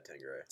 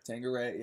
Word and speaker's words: Tanqueray: Is that how it's pronounced? Yeah, Tanqueray. Tanqueray.